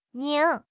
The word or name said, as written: 拧